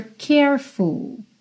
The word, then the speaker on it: careful: Depois, we gotta be extra careful. Careful, cuidado para não pronunciar a letra E aí that we don't build our hopes up too high.